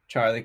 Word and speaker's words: Charley: So uh, if you're watching, I put my social media name there, Charley Coin.